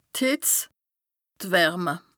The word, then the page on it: d' Hìtz, d' Wärme: Bas-Rhin; Strasbourg